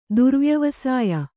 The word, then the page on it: ದುರ್ವ್ಯವಸಾಯ: Kannada